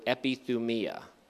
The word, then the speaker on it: epithumia: The phrase that I translated as intense longing is the Greek word epithumia. I'll spell that for you. E-P-I-T-H-U-M-I-A.